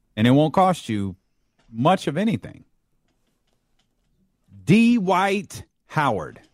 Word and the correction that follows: Dwight Howard: Dwight Howard, come on, man.